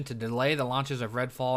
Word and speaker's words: Redfall: Starfield and Redfall. I said it right. I always want to say Starfall and Redfield.